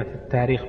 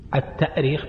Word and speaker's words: التأريخ: طبعا من حيث الأصل الاشتقاقي التأريخ، التأريخ بالهمز لأنها أرخ يؤرخ، الهمز أصلي فيه، أرخ يؤرخ تأريخا. وأما التاريخ فهو تسهيل، والتسهيل موجود في القرآن في الهمز في مواضع كثيرة عند بعض القراء، مثلا يعني معروف قراءة نافع مثلا في التسهيل وعدم القراءة بالهمز في مواضع كثيرة. وأيضا تنطق التوريخ لأن الهمزة تبدل بواو في بعض المواضع، وبعض أهل العلم يسميه التوريخ، توريخ يعني كأنه أرخ جعلها ورخ، يورخ توريخا، لكنها غير شائعة وإن استعملها بعض أهل العلم.